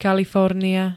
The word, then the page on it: Kalifornia: [-n-]